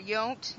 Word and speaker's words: Yount: Yount, Yount, Robin Yount.